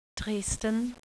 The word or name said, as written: Dresden